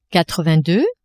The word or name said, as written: quatre-vingt-deux